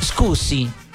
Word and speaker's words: scusi: Scusi.